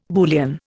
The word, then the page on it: Boolean: Indian